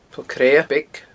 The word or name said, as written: Poll Creadha Beag